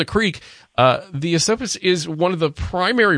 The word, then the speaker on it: Esopus: And I think I've correctly pronounced Esopus Creek. Is that Esopus? – Yes.